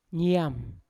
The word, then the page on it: niam: Yawa